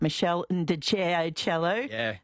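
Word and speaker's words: Meshell Ndegeocello: I'm going to say this wrong, but Mark and Ashley has pointed out Michelle Ndjeachello. – Is that how you do it? – Yep.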